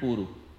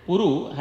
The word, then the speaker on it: Puru: That, this is a different Puru. And Puru has a short U. Puru has both U short. Puru has first U long and second U short.